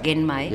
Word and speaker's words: Genmai: Genmai. – Yeah, it's pronounced with a hard G.